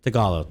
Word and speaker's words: Tagalog: Tagalog, thank you.